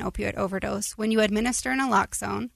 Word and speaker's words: naloxone: There was quite a conversation, and I still see the billboards, and I always mispronounce it, Naloxone. – Naloxone.